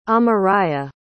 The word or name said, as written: amariah